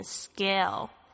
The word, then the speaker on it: scale: 所以呢，这个在这个 s 后面的这个 c 的发音呢，不发 k，而是发 g 的声音，所以是念 scale。